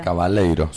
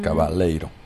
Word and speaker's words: Cavaleiro: Bien, entonces pasaba Fernando Cavaleiro. No sé si lo digo bien el apellido. – Cavaleiro, suena brasilero. Uh-huh. Cavaleiro.